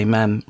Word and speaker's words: ma'am: then you must say ma'am, rhyming with jam. – What, rather than what? Mum? Rhyming with rum? – Mom, rhyming with smarm.